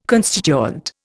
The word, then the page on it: constituent: Indian